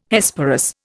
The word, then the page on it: Hesperus: Indian